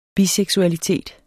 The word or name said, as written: biseksualitet